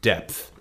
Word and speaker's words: depth: I need you to pronounce the TH at the end there, if you don't mind. Depth.